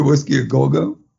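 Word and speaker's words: Whisky a Go Go: At the Whiskey A Go-Go? – Did I say that right? Yeah, I said that right.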